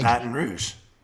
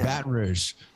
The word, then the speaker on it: Baton Rouge: So how do you say Baton Rouge? See, I keep saying like Baton Rouge. – Rouge. Okay. So I can say Rouge. Okay. Yeah. Baton Rouge.